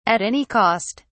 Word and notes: at any cost: British English

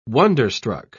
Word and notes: wonder-struck: British English

